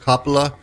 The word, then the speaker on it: Coppola: I know it's pronounced Coppola because I've talked to him on the phone.